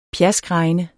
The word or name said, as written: pjaskregne